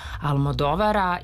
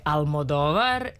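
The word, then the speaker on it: Almodóvar: To je novi film Pedra Almodovara i ja ću ponovo akcentovati tako, jer se kaže Una pelikula de Almodovar, iako smo mi navikli još od 90-ih, kada smo se ranih 90-ih upoznali sa njegovim radom i sa visokim potpeticama, se uvrežilo Almodovar.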